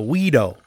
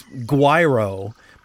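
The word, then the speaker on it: güiro: My percussionist, who was from Mexico, pronounced it the guido, like it's guiro, but it's he pronounced it guido, guiro.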